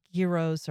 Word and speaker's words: gyro: Gyros are probably pronouncing it wrong. I say gyro the American way.